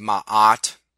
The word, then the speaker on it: Ma'at: which is spelled MA'AT. It might be pronounced Ma'at. Actually, I think that's how it is pronounced. I seem to recall my professor in ancient Near East religions pronouncing it Ma'at.